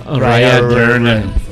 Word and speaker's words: O'Riordan: ¿Sí se pronuncia así? O'Riordan. – O'Riordan. – En irlandés, güey. – En irlandés. – Ver, acento irlandés.